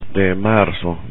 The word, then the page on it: de marzo: spanish